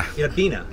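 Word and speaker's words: Itta Bena: is it pronounced Itabin? – Itabina. – Itabina. – Mm-hmm. That's what the natives call it.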